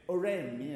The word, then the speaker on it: Oresme: I mean, Nicholas Oresme, I never know quite how to pronounce his name. How do you pronounce it? Oresme, yeah, I suspected that was it.